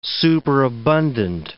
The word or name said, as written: superabundant